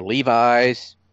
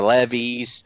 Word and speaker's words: Levi's: We got another ad, this one for Levi's. – Okay. – Or Levi's, as I've also heard it pronounced.